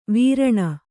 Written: ವಾರಣಿ

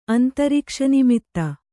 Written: ಅಂತರಿಕ್ಷನಿಮಿತ್ತ

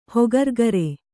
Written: ಹೊಗರ್ಗರೆ

